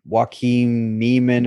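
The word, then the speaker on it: Joaquin Niemann: I saw that, I'm going to butcher his name, but Joaquin Neiman or whatever, however you say his name.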